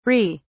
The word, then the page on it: ree: British English